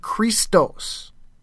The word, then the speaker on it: Christos: The word Christ is spelled with, it starts out with a chi, and which is pronounced C-H, and then this is your row here. So basically this is the Greek word Christos. Christos, for Christ.